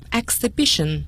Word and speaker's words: exhibition: Exhibition。 – Exhibition。 – 那么大家注意一下它的发音，如果它的动词是 exhibit， E-X-H-I-B-I-T Exhibit 可以做动词，展出做名词，可以代表展品，都是 Exhibit，大家一定要注意，但是展览会我们必须要使用 Exhibition 这样的读音，请大家一定要注意。